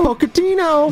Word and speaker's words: Pochettino: Pochettino? – Pochettino.